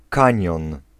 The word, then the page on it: kanion: [ˈkãɲjɔ̃n̪]